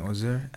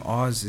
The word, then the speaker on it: Oz: So first off, my name is Chimisupten Ozer, and the stage name Oz is actually the short form of my real name Ozer. It's spelled O-Z-E-R, and it's just Oz is just O-double-Z.